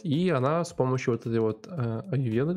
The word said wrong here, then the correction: Аюрведа: как правильно говорит Алина, Э, аюрведа вроде по-русски.